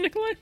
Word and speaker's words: Nikolaj: Is it Nikolai? I thought there was a J. Is that not pronounced? There is a J, but it's with an I.